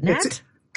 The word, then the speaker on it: Nat: I'm saying Nate, but is it Nat? – It is Nat. – I apologize. – Thanks for having me, Patty. – I added the silent E.